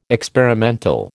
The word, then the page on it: experimental: American English